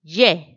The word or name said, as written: ye